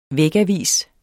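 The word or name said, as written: vægavis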